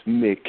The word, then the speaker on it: Mick: pronounced Mick M I C K Mulvaney, in case somebody wants to write a letter.